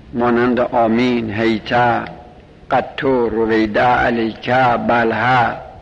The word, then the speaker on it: آمین: آمین بدون تشدید، آمین با تشدید لغت فصیحش نیست، آمین بدون تشدید باید تلفظ بشه، به معنی استجب است.